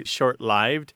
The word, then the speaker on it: short-lived: People think it should be short-lived. But that isn't the prevailing pronunciation. People at all levels of education and professional background and even linguistic authority unanimously say short lived or long lived is the prevailing pronunciation, even among the elite classes and all of the English speaking world.